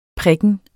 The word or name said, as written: prikken